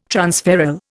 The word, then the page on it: transferral: Indian